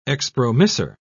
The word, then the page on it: expromissor: British English